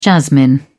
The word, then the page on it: jasmine: British English